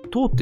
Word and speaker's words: toe: で、次が 2 曲目が、これはあの、結構インストゥルメンタルのバンドなんですけど、トーっていう、 TOE って書いてトーって読む